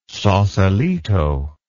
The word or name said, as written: Sausalito